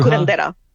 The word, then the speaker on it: curandera: Curandera. – Curandera.